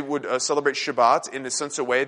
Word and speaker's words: Shabbat: In Hebrew, the word is actually pronounced Shabbat.